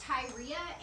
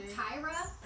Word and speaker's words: Tyria: I've heard her name pronounced both Tyria and Tyra. – Okay. – But it's spelled Tyria. It's T-Y-R-I-A.